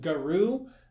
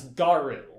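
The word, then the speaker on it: Garou: I believe you called the main antagonist of season 2 Garu, and okay, I probably did, yeah. – And it's Garu.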